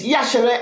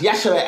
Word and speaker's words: Yashere: And my name is Yashere, and that's how it's pronounced, Yashere. And it's actually spelt I-Y-A-S-H-E-R-E. But when I started doing comedy, they kept mispronouncing the name and pronouncing the I, which is almost silent.